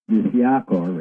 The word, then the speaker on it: Ciakar: They're called the reptilian, the original reptilian race called the Siacar. It's spelled, dig this, C-I-A-K-A-R. Isn't that funny? C-I-A-K-A-R.